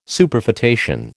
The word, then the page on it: Superfetation: American English